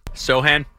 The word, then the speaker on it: Sochan: And you add Sochan, did I say that right? No? You can tell me what it was. – Sochan? – Yeah, you were close.